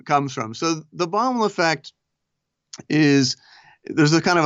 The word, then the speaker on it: Baumol: But what I found really compelling about the explanation in your book for this discrepancy, you point to something called the Baumol effect. I think I'm pronouncing it right, the Baumol right. effect. What is that and where does it come from? – Right. So William Baumol is an economist.